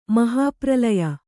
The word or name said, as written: ಮಹಾಪ್ರಳಯ